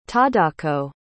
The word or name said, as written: tadako